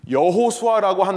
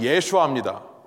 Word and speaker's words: 여호수아: 만일 여호수아라고 하는 인물이 예수님을 말하는 것이라면, 예수님을 상징하는 거라고 한다면, 여호수아라고 하는 것은 히브리식 발음이고요. 똑같은 단어를 아람 사람이 발음하면 예수아입니다.